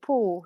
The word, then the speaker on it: Pau: How do you say Pau again? – Pau. – But you can say Pau, you know, Jean Pau.